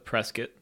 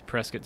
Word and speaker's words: Prescott: One of our photographers, Jojo Huckaba, and I made the trip to Prescott to go talk to Lynsie. Quick note it's pronounced Prescott, not Prescott. I've lived in Arizona all my life, and I've been pronouncing it wrong the entire time.